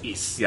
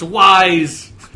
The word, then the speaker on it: Ys: I think, I'm pretty sure it's Ys. – Yeah, anyway. – I don't think it's wise.